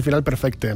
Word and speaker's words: final perfecte: Yo creo que termina el disco con ese final perfecta, que yo no sé nunca cómo pronunciar bien. Bueno, como eres valenciano, es mejor decir final perfecte, ¿no?